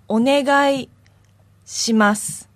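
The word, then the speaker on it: Onegai shimasu (お願いします): Onegai shimasu.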